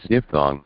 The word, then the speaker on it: diphthong: Okay, that is pronounced as... – diphthong. – Diphthong. – Diphthong, yeah.